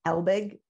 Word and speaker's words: Helbig: Helbig, yeah.